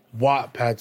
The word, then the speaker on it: Wattpad: How do you say it? Wattpad? – Wattpad. So it's Wattpad. Wattpad. – Wattpad, like a gigawatt. – Exactly, gigawatt. Okay. Exactly, like Wattpad.